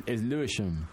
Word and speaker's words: Lewisham: It's Lewisham.